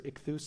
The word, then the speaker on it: ichthys: And by the way, the Greek word for fish, ichthys or ichthus, sometimes it's pronounced, was an acrostic poem.